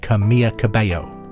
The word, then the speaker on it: Camila Cabello: It's spelt Camila Cabello, but I believe she pronounces it Camilla Cabello.